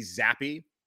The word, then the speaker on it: Zappe: They met with Sam Howell of North Carolina, a local kid, played at Sun Valley High School, and Bailey Zappi of Western Kentucky. Finally looked up how to pronounce his name, even though I don't think he'll be a Carolina Panther, but still showing some respect to Bailey Zappi,